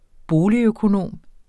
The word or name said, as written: boligøkonom